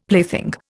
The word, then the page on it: plaything: Indian